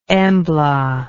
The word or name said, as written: embla